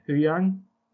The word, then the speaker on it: Huyang: Huyang.